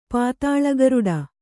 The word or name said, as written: ಪಾತಾಳಗರುಡ